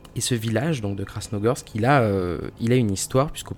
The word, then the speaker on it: Krasnogorsk: Et finalement, première hypothèse peut-être plus crédible, ces tests ont porté les soupçons sur la ville fantôme de, et attention à la prononciation, Krasnogorsk. – Bravo.